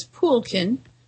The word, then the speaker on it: Polkehn: Polk. Wait a minute, I learned how to pronounce his name, Poulkin. It's spelled P-O-L-K-E-H-N, but it's Poulkin.